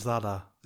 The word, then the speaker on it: Zadar: Wie spricht man das aus? – Zadar. Zadar. Zadar.